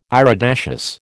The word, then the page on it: iridaceous: American English